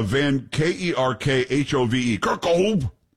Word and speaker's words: Van Kerkhove: It's Van Kerkhove. I'm pretty sure that's how you pronounce it.